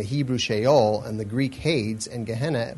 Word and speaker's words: Hades: or Hades, or Hades, as I think it is properly pronounced in a Greek term, Hades.